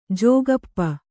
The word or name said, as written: ಜೋಗಪ್ಪ